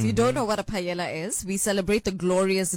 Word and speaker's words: paella: It's a Spanish word? – So the L is silent. It's Paella Day.